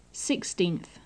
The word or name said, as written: sixteenth